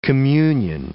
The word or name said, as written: communion